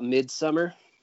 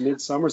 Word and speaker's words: Midsommar: Is it just Midsommar? Is that Midsommar? – Aster, the director, has said that either Midsommar or Midsommar, he's like, either one's fine.